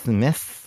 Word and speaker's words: Metz: Metz. Comment tu la prononces Metz. – Metz. Comme, comme, la messe du dimanche.